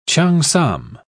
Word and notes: cheongsam: British English

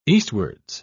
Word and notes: eastwards: British English

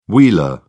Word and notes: wheeler: British English

